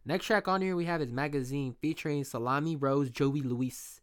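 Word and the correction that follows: Salami Rose Joe Louis: No, I didn't say that right. Salami Rose Joe Lewis.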